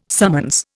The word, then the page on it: summons: Indian